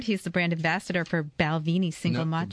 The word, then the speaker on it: Balvenie: It's Balvenie. – Balvenie.